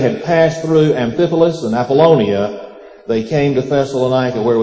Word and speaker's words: Apollonia: In verse 1, there's the mention of Amphipolis and Apollonia, and then also Thessalonica. I think I said that wrong. It's Apollonia.